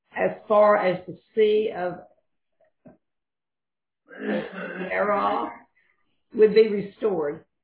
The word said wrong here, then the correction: Arabah: So it's Lebo Hamath and the Sea of Arabah.